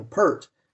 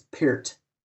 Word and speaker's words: Peart: I have, Jack is at one of his first drum lessons, and we were talking about Neil Peart, and the drum teacher says, well, you know, actually it's Peart. – What? – You know, no. And he pronounces it Peart.